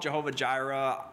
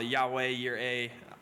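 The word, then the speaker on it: Yahweh Yireh: Some people call it Jehovah Jireh, you know, Yahweh, Yireh, whatever you want to do for your Hebrew pronunciation, right?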